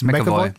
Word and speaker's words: McAvoy: James McAvoy? – James McAvoy.